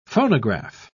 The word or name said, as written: phonograph